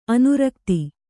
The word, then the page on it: ಅನುರಕ್ತೆ: kannada